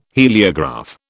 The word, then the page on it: heliograph: British English